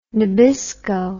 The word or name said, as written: Nabisco